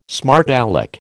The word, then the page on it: smart alec: American English